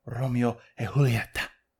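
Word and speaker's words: Romeo y Julieta: But this is the Robusto Romeo and Juliet 1875, otherwise pronounced as Romeo e Julieta.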